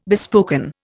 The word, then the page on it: bespoken: Indian